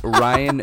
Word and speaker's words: Ryan: Mine is Ryan Durgy, R-Y-A-N. – It's your name. – D-U-R-G-Y, because I am original and creative like that.